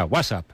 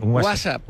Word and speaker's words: WhatsApp: No, peor aún, con acento en la primera. WhatsApp, ¿no? – WhatsApp. Ahí está.